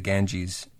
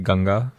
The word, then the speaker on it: Ganga: The second most polluted river is the Ganges in India. Is that how you pronounce that? – Right. Yeah. But it's actually Ganga. – Ganga. – Yeah. It's again whitewashed.